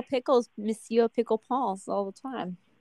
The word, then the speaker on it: Monsieur: Well, I guess it's Monsieur. Is that how you say it? Monsieur Lancelin, that's the father, went to his office. – Monsieur? – Yeah, that's it.